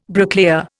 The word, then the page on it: bricklayer: Indian